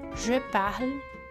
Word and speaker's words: parle: No caso do verbo parler, falar, fica assim: je parle, tu parles, il parle, elle parle. Tudo com o é mudo no final, pronunciando somente o L, sem fazer o som da vogal no final.